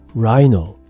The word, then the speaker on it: Rhino: Rhino。これ、動物のサイと同じスペル、そして発音ですね。